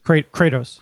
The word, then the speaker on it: Kratos: Kratos. – Kratos, thank you.